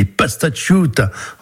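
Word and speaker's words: pastasciutta: les pastachiuta antifascista, j'espère que je prononce bien. – Pastachiuta. – Voilà, pastachiuta, pardon.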